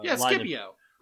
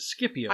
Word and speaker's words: Scipio: Because, for example, there is a family of generals from Rome that are often pronounced as the Scipio. – Scipio. – Right, and I always pronounce it as Scipio. – I've seen a whole bunch of videos on the Scipio family. – Right, and I think I got the hard C pronunciation from everybody.